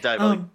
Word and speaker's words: Diabolik: Diabolik? However you pronounce that. – Diabolik, yes.